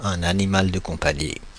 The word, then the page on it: animal de compagnie: /a.ni.mal də kɔ̃.pa.ɲi/; Paris